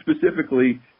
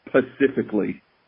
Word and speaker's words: specifically: He mispronounces specifically, and it's during a f***ing ad, and he says pacifically.